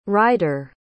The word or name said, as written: ridere